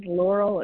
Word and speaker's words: Lauryl: My name is Laurel. It's spelled L-A-U-R-Y-L, and B is my last initial, and I'm calling in from Chicago, Illinois.